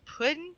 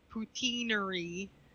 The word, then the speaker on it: poutine: Poutine.